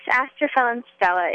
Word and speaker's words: Astrophil and Stella: Yes, Astrophil and Stella.